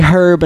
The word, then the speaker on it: herb: Americans don't pronounce herb. – Oh, my God. – Like, for real. Okay, so you say herb and homage.